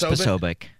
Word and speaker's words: Posobiec: Is it Posobiec? – It's Posobiec.